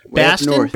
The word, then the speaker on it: Boston: Baston? – Baston? – Yes, how it's pronounced.